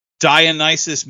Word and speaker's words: Dionysus: So I want to talk about that for a second with, and I hope I'm saying this right, Dionysus Metal Cult. – Oh, you got that right. That's, yeah, you nailed it, brother.